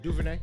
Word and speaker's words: DuVernay: So Ava DuVernay, did I say that right? – Yeah, DuVernay.